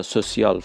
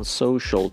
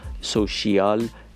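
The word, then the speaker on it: social: پس ما سوسیال فرانسه هستش، برگرفته از تلفظ فرانسه، از اونایی که میخوان این رو به صورت انگلیسی ادا بکنن باید بگن سوشال، سوشیال نداریم، انگلیسی نمی باشد، سوشال، شل.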